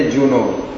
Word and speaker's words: यूनोर: इसमें जो यूनो लिखा है तो या के साथ बिंदी लगी है, हाँ बिंदी लगी है, तो इसमें जो उच्चारण का जो नियम है वो ये, या में अगर बिंदी लगी है तो जा बोलना है, ठीक है, तो आप यूनोर की जगह बोलेंगे जूनोर, जूनोर, ठीक है।